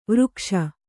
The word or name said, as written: ವಿರೂಕ್ಷ